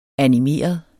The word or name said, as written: animeret